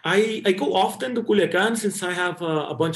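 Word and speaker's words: Culiacán: And you recently visited Culiacan, right? If am I saying it right? – Yeah, Culiacan, exactly.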